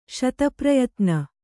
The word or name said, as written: ಶತಪ್ರಯತ್ನ